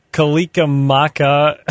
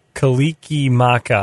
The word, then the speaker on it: Kalikimaka: Mele Kalikimaka. I'm not even saying it right. I know it. Kalikimaka, I believe. – There you go.